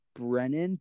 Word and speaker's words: Brennan: All right, my Venmo is Brennan, B-R-E-N-N-A-N, dash Glorioso, G-L-O-R-I-O-S-O.